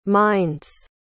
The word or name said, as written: minds